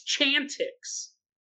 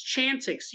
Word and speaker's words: Chantix: Like, it's clearly, it's Chantix. It's Chantix. You look at the word, it is clear there is not an R in sight.